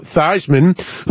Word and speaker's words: Theismann: We're talking about Joe Theismann, who was convinced to change the pronunciation of his last name. Remember, it was Theismann. They changed it to rhyme with Heisman.